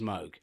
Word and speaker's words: Moog: The actual pronunciation of the guy's name is Moog.